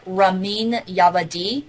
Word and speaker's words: Ramin Djawadi: but it's like Ramin Djawadi, mm-hmm. but it's spelled RaminDjawadi.